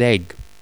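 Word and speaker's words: Egge: And, you know, I probably shouldn't be picking on your name here, but because my last name's Egg, but it's not spelt Egg. It's spelt E-double-G-E. Still pronounced the same though.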